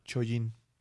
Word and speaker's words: Chojin: chojin C-H-O-J-I-N. Chojín, ¿no? Pero se pronuncia Chojin.